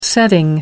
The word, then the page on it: setting: American English